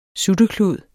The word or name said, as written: SUTTEKLUD